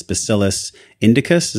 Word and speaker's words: Bacillus indicus: It was HU36, this Bacillus indicus.